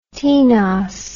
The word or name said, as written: tenos